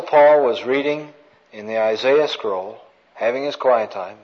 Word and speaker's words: Isaiah: Isaiah, is that the correct pronunciation? That's right. She'd be right, Mike. Isaiah.